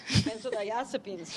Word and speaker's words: benzodiazepines: Okay, this I had to write down because as a print journalist, I don't have to know how to pronounce benzodiazepines, right? – Benzodiazepines, yes.